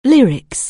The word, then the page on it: lyrics: anglais